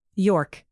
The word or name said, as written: york